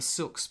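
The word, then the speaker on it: Syilx: But the Okanagan peoples, the Silks peoples, is I believe the correct pronunciation of that.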